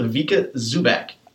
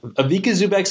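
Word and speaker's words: Ivica Zubac: I believe we're going with Avika Zubac. – Avika Zubac sounds perfect to me.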